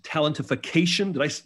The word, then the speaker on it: Talentification: He's authored 20 books and titles such as Talentification. Did I pronounce that right? – You did it good.